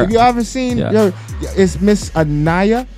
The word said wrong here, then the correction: Anya: No, Anya. – Anya. – A-N-Y-A. – Anya, I said it wrong. Sorry. A-N-Y-A.